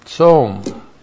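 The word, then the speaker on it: Tsom (צוֹם): And then we have the noun tsom. T-S-long-O-M. Tsom.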